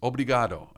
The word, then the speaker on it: obrigado: And he says, obrigado.